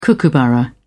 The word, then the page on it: kookaburra: British English